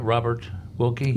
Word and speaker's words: Robert Wilkie: Do you think, did I say that right, Robert Wilkie? – You did.